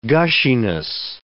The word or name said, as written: gushiness